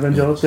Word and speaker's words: Vangelis: Yeah, Vangelis, yeah.